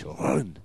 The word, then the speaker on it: Rouen: Actually, well, how's it pronounced in French? – Rouen.